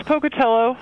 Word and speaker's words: Pocatello: Yeah, it's Pocatello, Idaho.